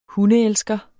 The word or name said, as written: hundeelsker